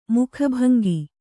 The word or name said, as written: ಮುಖಭಂಗಿ